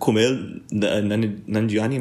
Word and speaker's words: Kumail Nanjiani: Even people like Kumail Nanjiani, am I pronouncing that right?